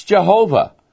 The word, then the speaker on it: Jehovah: Well, if you believe the King James Bible, you know how to pronounce it. It's Jehovah, also known as Lord.